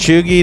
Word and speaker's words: cheugy: Chuggy is spelled C-H-E-U-G-Y.